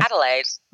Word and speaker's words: Adelaide: How do you say the town you grew up in? Adelaide? Did I say that right? – Adelaide. Okay. Yeah, Adelaide.